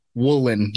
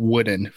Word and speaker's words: Wooden: I saw in your comments people were mad that I pronounced it woolen, not wooden, for the defensive lineman rookie.